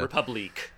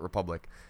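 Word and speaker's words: République: Republique. – Republique, if you want to pronounce it with the way it has an accent. Okay. But everyone in the game calls it Republic.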